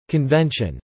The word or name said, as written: convention